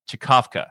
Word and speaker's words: Czkawka: It's spelled C-Z-K-A-W-K-A or Chikafka. – I think that's how you pronounce it.